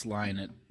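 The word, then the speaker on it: Lynott: How do you pronounce it? I think it's Linnet. – No, it's Linnet.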